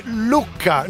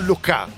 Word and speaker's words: Lucca: Allora, dite Lucca, non Luca.